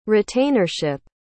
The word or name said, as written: retainership